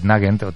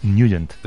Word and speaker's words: Nugent: Hombre, siempre lo hemos llamado en el barrio Ted Nugent. – Claro, yo era de Ted Nugent. – Pero la pronunciación correcta es Nugent.